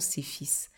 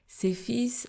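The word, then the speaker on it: SEPHIS: fils l'association CEPHIS. CEPHIS qui s'écrit S-E-P-H-I-S. E CEFIS euh, a participé à la construction de bureaux genre à travers les commissariats, certains commissariats en Côte d'Ivoire.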